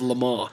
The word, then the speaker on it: Le Mans: It's a play on the 24 Hours of Le Mans. And which is spelled L-E space M-A-N-S, so yes. Lemans.